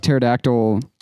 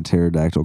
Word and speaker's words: pterodactyl: Because the pee is silent. Because pterodactyl starts with a P.